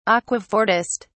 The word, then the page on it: aquafortist: British English